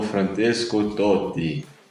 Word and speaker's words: Francesco Totti: A resposta correta é Il Capitano Francesco Totti.